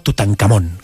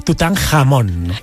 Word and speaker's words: Tutankamón: La Real Academia de la Lengua dice que hay que pronunciarlo y decirlo como Tutankamón. Pero escrito, o si preguntásemos a los ingleses o a los franceses, nos dirían que no se pronuncia Tutankamón.